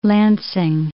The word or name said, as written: Lansing